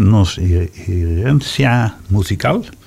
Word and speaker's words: Nos Herencia Musical: Met als thema Nos Herencia Musical. – Heel goed. – Ja, zeg ik het goed? – Je zegt het goed, ja.